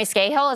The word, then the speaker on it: Scahill: The guys from The Intercept, so Ryan Grimm and Jeremy Scahill, is that how you pronounce it? – Yes.